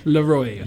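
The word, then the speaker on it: LAROI: Leroy, I believe it is. – Like just LaCroix, like but without the C.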